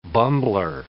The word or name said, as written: bumbler